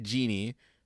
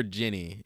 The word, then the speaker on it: Ginny: But we get Jeannie, or I think it's Jeannie or Jenny. Either way, it's spelled G-I-N-N-Y.